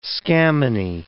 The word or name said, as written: scammony